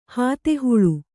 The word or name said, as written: ಹಾತೆಹುಳು